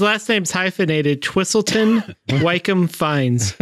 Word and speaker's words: Fiennes: And it's spelled Fiennes. Yeah. But it's pronounced Fiennes.